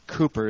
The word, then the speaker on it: Cowper: It was written by William Cowper, or Cooper is the correct pronunciation, but it's spelled Cowper.